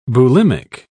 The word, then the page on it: bulimic: British English